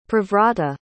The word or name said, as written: privrata